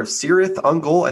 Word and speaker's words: Cirith Ungol: Number two on the docket today, we're going to be talking about a band called Cirith Ungol, or Cirith Ungol, I think is how they prefer it to be pronounced, which is actually a reference to the Pass of the Spider in the Tolkien Lord of the Rings books.